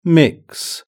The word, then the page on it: mix: British English